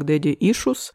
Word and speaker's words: Daddy Issues: Мне оставили комментарий, что Daddy Issues произносится как Daddy Issues, о чем вы знаете, если слушали предыдущий выпуск, где мы разбирались с этим вопросом мироздания.